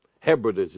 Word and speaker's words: Hebrides: Hebrides is the right pronunciation.